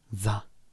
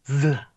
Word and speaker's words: SZA: It's spelled S-Z-A, so it's more like Z-A. – Z-A. – Z-A.